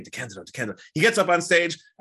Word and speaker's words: DeCandido: I'm Lisa Meese, and today I'm talking to Keith DeCandido. Did I say that right? – You did. Congratulations, you passed the first test.